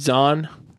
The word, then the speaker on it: Zahn: Z-A-N-E. – Okay. – And Steve Zane is Z-A-H-N. – Isn't that Steve Zahn? – Yeah, that's Zahn. – Isn't it Zahn? – We know an Zahn that is not related to Steve.